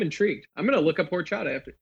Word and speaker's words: horchata: H-O-R. – But you basically don't pronounce it. Yeah. – Horchata. – Nice. That's really good. – So it's like a silent H.